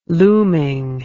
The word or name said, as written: looming